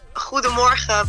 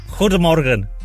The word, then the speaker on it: Guten Morgen: Did I say that right? – Well, it's Guten Morgen, but you're almost there. – Guten Morgen. Is that Yeah, that's it. – I'm going to learn some Dutch from you this morning, if that's possible.